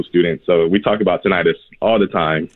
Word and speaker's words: tinnitus: So the ringing in the ear is officially called tinnitus. – Hey! – Then how come we're saying tinnitus or whatever it's called? – It's spelled weird. It's spelled like tinnitus, but it's tinnitus.